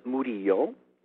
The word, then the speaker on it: Murillo: Close. It's in Spanish. It's Murillo.